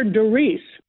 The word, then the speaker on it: Darice: No, actually, Dar is short for Doris, and no one can pronounce it the way it's spelled. – Doris. – How is it spelled? – Spell it, please. – D-A-R-I-C-E.